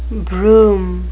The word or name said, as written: brougham